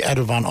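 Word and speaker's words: Erdoğan: Ja, aber zum Beispiel dieser Verrückte vom Bosporus, Erdogan, der wird ja wirklich Erdogan ausgesprochen, ne? In der Türkei.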